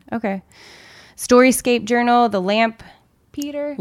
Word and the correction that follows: Lampeter: Lampeter. – The Lampeter Review.